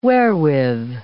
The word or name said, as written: wherewith